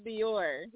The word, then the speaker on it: Dior: You said my name wrong. My name is Dior.